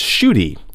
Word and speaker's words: Ncuti: Yeah, it's Shuti. It's spelled N-C-U-T-I, but it's used to pronounce Shuti.